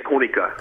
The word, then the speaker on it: Konica: Which Japanese, they say Konica.